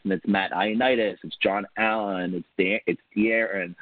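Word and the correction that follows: Da'Ron: Or Deron, excuse me, didn't mean to pronounce his name wrong.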